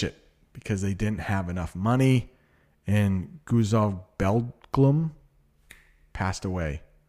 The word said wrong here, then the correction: Gutzon Borglum: The correct pronunciation of Guzog Borglum. Gutzon Borglum. Gutzon Borglum.